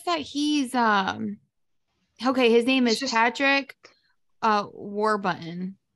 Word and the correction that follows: Warburton: It's Patrick Warbutton? – I'm sorry, Warburton. – Okay. Patrick, I can go with. Warbutton? – W-A-R-B-U-R-T-O-N. Warburton. Warburton.